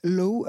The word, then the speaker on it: Law: je vais parler d'un film de hongkongais de 88 qui s'appelle Painted Faces, qui est un documentaire alors de Alex Low. à mon avis, ça ne se prononce pas du tout comme ça, c'est L-A-W. Euh, mais bon, la prononciation anglaise voilà.